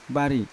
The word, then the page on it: bari: Yawa